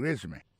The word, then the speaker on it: résumé: Alors là encore, si on a parlé de accent, accent tombé sur premier syllabe. It's called a résumé.